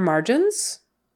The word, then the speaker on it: margin: Margin, R-R. Gin, gin, that G is like a J, J sound, j, j, j. Margin, silver vowel, I, short I. Margin. Are your margins padded? Margin. Accent on the first syllable, margin.